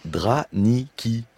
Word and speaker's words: draniki: Attention, est-ce que je prononce bien? Les draniki. – Oui.